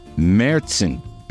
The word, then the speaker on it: Märzen: Now featuring two locally made German-style Märzen beers. I said it right. They have the umlaut over the A, like Motley Crue.